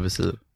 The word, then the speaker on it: visir: Weisier. Vaisir. Eller visir. Visir. Är det så man säger?